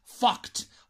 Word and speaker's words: fucked: pero F-U-C-K-E-D, fucked, fucked, y se, hay que pronunciarlo con una T.